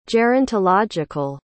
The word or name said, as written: gerontological